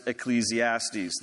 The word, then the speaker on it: Ecclesiastes: We kind of anglicize that Greek word, and, and more so we pronounce it Ecclesiastes, this book.